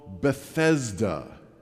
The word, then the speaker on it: Bethesda: John tells us it was called Bethesda. That's the popular pronunciation of this word. An alternative and possibly more likely pronunciation is Bethzatha.